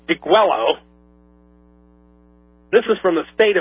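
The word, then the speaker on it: Degüello: It was an anonymously written document entitled, and I'm going to give you the spelling because it's a Spanish word, D-E-G-U-E-L-L-O. Now, my initial gut feeling from what Spanish I do know is that would be pronounced Deguello. But for simplification purposes, we're just going to call it Deguello because that's the phonetic pronunciation of it, folks. D-E-G-U-E-L-L-O.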